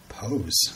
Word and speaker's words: Poes: I'm gonna spell it for you. P O E S. – Pose. Oh man.